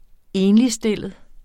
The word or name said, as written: ENLIGSTILLET